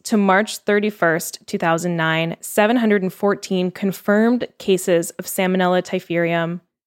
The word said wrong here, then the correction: Typhimurium: Oh, I think I'm saying that wrong. Typhimurium. Oops.